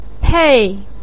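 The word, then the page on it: pei4: tone 4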